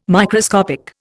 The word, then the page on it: Microscopic: Indian